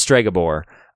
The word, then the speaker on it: Stregobor: I said Segobor. I've been saying it wrong this whole time. It's Stregobor. So different.